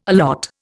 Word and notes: allot: Indian

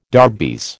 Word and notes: darbies: American English

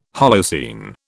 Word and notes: Holocene: British English

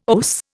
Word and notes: OAS: Indian

